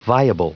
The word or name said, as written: viable